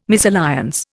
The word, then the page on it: misalliance: Indian